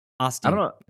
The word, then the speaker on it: Osteen: Is it Osteen? – Osteen.